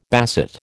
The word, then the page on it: basset: American English